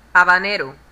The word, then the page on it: habanero: /ˌ(h)ɑːbəˈnɛəroʊ/